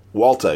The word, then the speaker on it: Walta: Gabe Hernandez and Walata and, am I saying it right? Walta, excuse me.